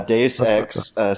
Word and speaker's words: Deus Ex: Deus Ex